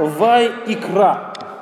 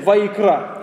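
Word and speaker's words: Вайикра (ויקרא): Вот по-еврейски она произносится как «Вайикра». Вот, если хотите блеснуть где-нибудь эрудицией, можно сказать «Вайикра».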